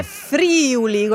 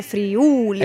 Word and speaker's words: Friuli: O dire Friuli con l'accento sulla prima I. No, è Friuli.